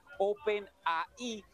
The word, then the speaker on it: OpenAI: Es bien interesante porque recuérdense que compite contra OpenAI, que es como se pronuncia en inglés, OpenAI, que es la compañía que nos brinda ChatGPT a mis espaldas.